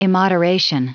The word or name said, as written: immoderation